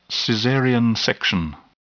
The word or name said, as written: cesarean section